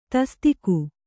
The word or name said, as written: ತಸದೀಕು